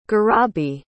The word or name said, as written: garabi